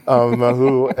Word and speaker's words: māhū: I'm so glad that I pronounced it properly, māhū, and tell the folks what that means.